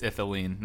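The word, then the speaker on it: Ithlinne: It's Ithilene, not Illithine. – Ithilene. – It's Ithilene. – I'm the worst with names. – It's I-T-H. It's just Ithilene, like not L. It doesn't say. Ithilene.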